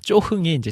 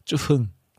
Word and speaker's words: 쭈흥: 이 노래가 오직인데 사람들에게는 조그만 예수님 이렇게 들려주면 안 되니까 이거는 다시 녹음해야 된다 해서 쪼흥이 아니라 쭈흥. 네, 이렇게 원래 다시 발음을 교정해서 녹음했던 기억이 나네요.